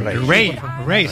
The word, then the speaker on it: Raye: Ray.